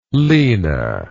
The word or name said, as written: lina